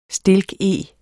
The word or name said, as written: stilkeg